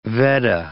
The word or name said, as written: Veddah